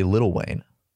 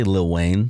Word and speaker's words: Lil Wayne: No, you say Little Wayne. – No, you don't. – Yes, you do. – Not if you know his name. – It's pronounced Lil Wayne. – It's pronounced Lil Wayne. How it's spelled. – L I L, but it's pronounced Little Wayne.